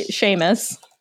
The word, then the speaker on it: Sheamus: Uh, that would be Sheamus.